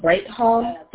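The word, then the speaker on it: Bright-Hall: My first name is spelled B-R-Y-A-N-N-A. – B-R-Y-A-N-N-A. Thank you. And your last name? – Yes, ma'am. Breitholm, that is B-R-I-G-H-T hyphen H-A-L-L. – B-R-I-G-H-T hyphen H-A-L-L? – Yes, ma'am.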